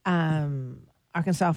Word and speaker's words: Arkansas: Arkansas Folklife Festival.